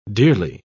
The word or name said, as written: dearly